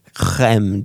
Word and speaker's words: crème: S-F-U-M-A-T-O, next word, R-A-B-A-R-B-A-R-O. And then 1/4 ounce of crème de violette. Crème, did I get that right? Crème. – Beautiful, absolutely beautiful.